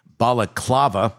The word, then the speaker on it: balaclava: Prosecutors say the Balaclava. Did I say it right? – You got it.